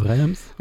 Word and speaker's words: Reims: Oh, Reims.